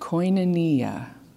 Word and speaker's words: koinonia: That first word that you may not recognize is pronounced koinonia. And it is the Bible word for fellowship.